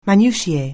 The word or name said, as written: minutiae